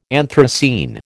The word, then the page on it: anthracene: American English